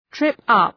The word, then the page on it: trip up: ‘trıp,ʌp